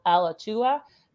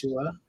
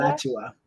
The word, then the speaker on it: Alachua: That's Alachua, Bradford, Broward. – Alachua. – Oh, thank you. How do you say that? Alachua. – Alachua. – Okay, awesome.